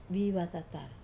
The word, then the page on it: vatatar: Wamesa